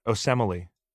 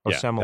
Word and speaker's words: Osemele: And obviously, Osmeli, how do you say that name? I've never gotten it. Osemele. – Yeah, Osemele. That one.